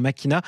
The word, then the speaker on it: Machina: Alors, Machina, ça s'écrit en français Machina, mais on le prononce à l'italienne, évidemment. Machina, voiture de luxe, voiture sportive, avec des performances assez spectaculaires pour une voiture à hydrogène, Olivier Lombard.